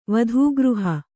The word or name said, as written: ವಧೂಗೃಹ